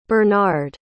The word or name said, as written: burnard